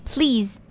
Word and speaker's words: please: 首先就是第一个，please，please，它的尾音是 z 而不是 s。 please。